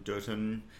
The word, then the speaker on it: Durton: So Dirt Town centers around the disappearance of one of the young girl citizens of Dirt Town, Durton, as I should pronounce it,